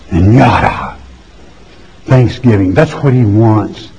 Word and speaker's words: yahdaw: And it comes from yada, Y-A-H-D-A-W, yada.